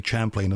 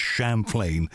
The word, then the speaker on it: Champlain: I've tended to say Champlain, and of course it is Champlain, as in Champagne.